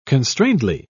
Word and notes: constrainedly: British English

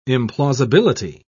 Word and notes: implausibility: British English

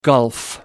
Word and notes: gulf: American English

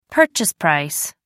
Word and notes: purchase price: American English

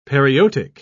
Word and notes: periotic: British English

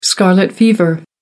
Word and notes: scarlet fever: American English

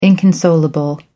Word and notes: inconsolable: American English

